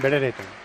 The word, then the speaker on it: Brereton: Yo lo he escuchado siempre como Ben Brereton.